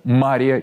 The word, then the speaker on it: Мария: В Мариино Стояние поминается Мария Египетская. Ударение на первый слог, так как на второй – Мария, он делается только в отношении Пречистой Девы Богородицы.